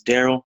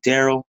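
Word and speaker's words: Darrel: My name is Daryl Rich Brian. I'm also on Instagram, Daryl Rich Brian. – That's D-A-R-R-E-L space Rich as it's spelled space B-R-Y-A-N.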